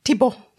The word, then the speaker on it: Thibault: this Eloise Thibault lady. – Yes, or as Tatiana says, Thibault. – You're correct. I said it wrong. I didn't say it the French way.